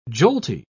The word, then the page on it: jolty: British English